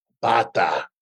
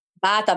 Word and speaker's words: bata: Listen, it's called a bata. – Bata. – Bata. That's how you say it in Spanish.